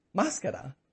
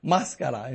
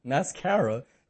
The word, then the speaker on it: máscara: Because you know what the word in Spanish for mask is? Máscara. Like, that's the word. Máscara in Spanish is mascara. Pronounced in English, mascara. It's máscara.